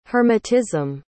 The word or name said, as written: hermitism